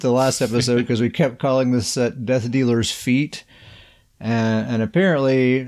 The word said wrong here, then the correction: fete: this word, F-E-T-E, it's a French word, according to Grizzly, and it's pronounced fet, right? Isn't that right, fet? – Yeah, it's fet.